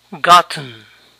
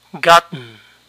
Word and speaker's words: gotten: Conseguido se pronuncia gotten, gotten. Pero hay otra forma de pronunciar esta palabra. Gotten, gotten. Lo que hice fue detenerme en la primera sílaba, ga, y luego dejé de pronunciar la T y solo pronuncié la N.